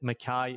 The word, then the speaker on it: Mackay: So the biggest reason was just the rhyming, so that if you even read it, you would hopefully understand that Mackay is said the way that it rhymes with guy. So Niall Mackay, the podcast guy, kind of rolls off the tongue a bit.